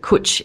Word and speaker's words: Cwtch: And I do believe that Cwch is the correct pronunciation, though it's spelt C-W-T-C-H.